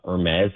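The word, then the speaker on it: Hermès: You know, Apple has a deal with, I don't even know how to say this properly, but Hermes, I think you call it. – That's perfect.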